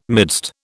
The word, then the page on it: midst: British English